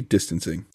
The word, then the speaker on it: distancing: This is not how you say distancing. – They moved into the social distancing. – Extra syllable there in distancing. Distancing. – Distancing. – Sound like he tried to throw a New York correctional facility into the middle of distancing.